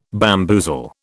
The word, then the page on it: bamboozle: British English